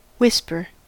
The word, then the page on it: whisper: American English